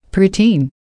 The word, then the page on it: preteen: American English